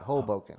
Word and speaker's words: Hoboken: Say it like that. Hoboken.